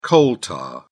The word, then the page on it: coal tar: British English